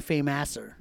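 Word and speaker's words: Fameasser: Fame Asser. – It's supposed to be Fame Asser. – Yeah. – But it's spelled Fame Sir.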